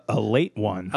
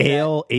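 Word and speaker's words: Ale-8-One: If you just read that label, would you know that it's pronounced a late one? – I would read ale that. Ale 8-1. – I wouldn't know that was a one. I would think that was a period. And I would think the 8 was a stylized S. I would have pronounced that Ailes.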